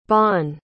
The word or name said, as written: bonne